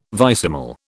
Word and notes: vicesimal: British English

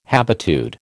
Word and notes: habitude: American English

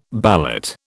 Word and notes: ballot: British English

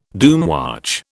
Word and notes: doomwatch: British English

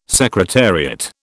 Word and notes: secretariat: British English

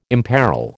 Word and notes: imperil: American English

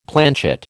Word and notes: planchet: American English

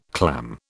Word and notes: clam: British English

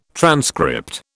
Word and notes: transcript: British English

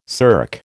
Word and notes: SERC: American English

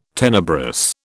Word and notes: tenebrous: British English

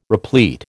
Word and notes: replete: American English

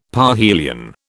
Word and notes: parhelion: British English

